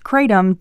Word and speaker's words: kratom: Perhaps you've heard of kratom, sometimes pronounced kratom, depending on who you ask.